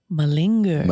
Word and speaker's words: malinger: Word of the day is mullinger. Is that how you say it? Malinger.